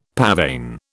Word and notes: pavane: British English

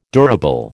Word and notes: durable: American English

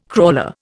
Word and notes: crawler: Indian

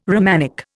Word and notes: Romanic: Indian

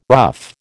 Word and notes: rough: American English

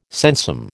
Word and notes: sensum: American English

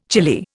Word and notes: chilly: Indian

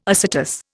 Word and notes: acetous: Indian

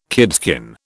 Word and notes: kidskin: British English

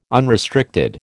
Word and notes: unrestricted: American English

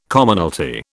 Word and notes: Commonalty: British English

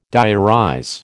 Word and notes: diarize: American English

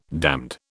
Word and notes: damned: British English

